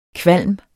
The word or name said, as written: kvalm